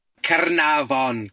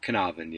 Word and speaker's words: Caernarfon: Caernarfon Castle is, he put some effort into the Welsh pronunciation, but Caernarfon, yeah.